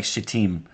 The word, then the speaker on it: Shittim: and it makes them giggle because it doesn't look like Shittim. But that's how you say it.